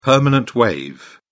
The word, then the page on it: permanent wave: British English